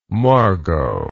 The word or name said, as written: Margot